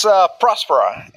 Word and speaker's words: Próspera: Is it pronounced Próspera or Próspera? – It's Próspera. – And I've been saying Próspera this whole time. – It has the accent mark to resolve literally that exact question.